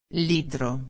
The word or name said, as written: litro